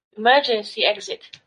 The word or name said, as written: emergency exit